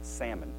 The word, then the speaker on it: Salmon: It's how you pronounce it, too. Salmon. S A L M O N.